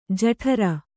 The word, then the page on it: ಜಠರೆ: jaṭhare